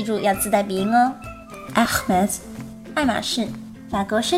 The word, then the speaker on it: Hermès: 这个品牌名字也是很容易读错的，因为呢，它也是一个法语词，字母 H 在法语中是不发音的，所以呢，应该是 Hermes，Hermes。同样，R 是小舌音，有一个类似的音，记住要把声音往喉腔中间压一压哦。 Hermes。